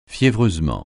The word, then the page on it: fiévreusement: francais